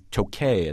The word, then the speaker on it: Tokaj: It's spelled T-O-K-A-J, by the way, but it is pronounced in Hungarian. I've been there, so I know.